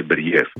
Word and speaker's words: Μπεριέφ: Μπέριεφ, πού τονίζεται πρώτα απ' όλα για να το λέμε και σωστά? – Νομίζω το λένε Μπεριέφ.